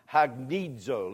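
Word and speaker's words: hagnizo (ἁγνίζω): It's pronounced hagnizo. Let's say that one.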